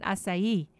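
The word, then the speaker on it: açaí: And she said, you do know that we pronounce it acai with the accent on the E.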